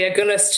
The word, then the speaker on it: Agulhas: So the actual ship that was doing the searches, the Agulhas 2, I don't know if I'm pronouncing that right. – That's right, the Agulhas 2.